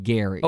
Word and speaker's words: Gerry: Hey, by the way, we do have to correct the pronunciation of Bohannon's first name. Apparently, even though it's spelled G-E-R-R-Y, it's Gary.